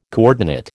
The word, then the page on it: coordinate: American English